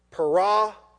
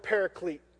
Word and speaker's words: parakletos: That word helper comes from the Greek word parakletos. Parakletos. Everybody say parakletos. Para, the prefix, that means it's, you might have heard the word paraclete. That's an English pronunciation of the word.